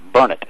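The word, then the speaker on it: Burnet: Pretty close. Burnett. It's pronounced Burnett.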